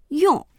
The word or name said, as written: yong4